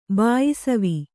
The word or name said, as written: ಬಾಯಿಸವಿ